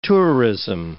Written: tourism

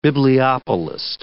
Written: bibliopolist

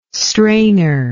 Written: strainer

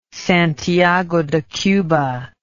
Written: Santiago de Cuba

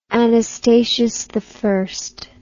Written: anastasiusi

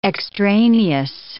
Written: extraneous

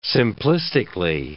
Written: simplistically

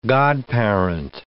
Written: godparent

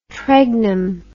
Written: tregnum

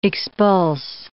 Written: expulse

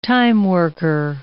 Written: timeworker

